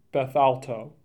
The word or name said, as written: Bethalto